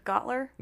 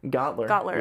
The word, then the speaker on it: Goettler: All right, so you said Gottler? – Gottler.